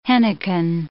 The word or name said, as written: henequen